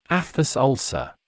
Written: aphthous ulcer

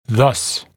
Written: thus

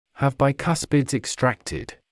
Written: have bicuspids extracted

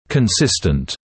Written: consistent

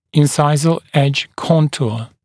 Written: incisal edge contour

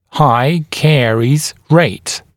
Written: high caries rate

